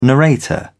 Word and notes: narrator: British English

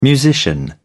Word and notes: musician: British English

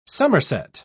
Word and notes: summerset: British English

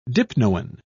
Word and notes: dipnoan: British English

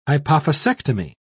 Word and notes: hypophysectomy: British English

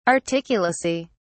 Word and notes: articulacy: British English

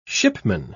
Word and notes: shipman: British English

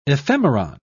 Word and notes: ephemeron: British English

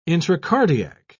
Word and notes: intracardiac: British English

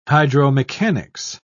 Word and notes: hydromechanics: British English

